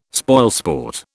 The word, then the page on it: spoilsport: British English